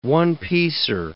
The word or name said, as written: one-piecer